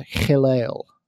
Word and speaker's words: Hillel: There was Shammai and Hillel.